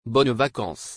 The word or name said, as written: Bonnes vacances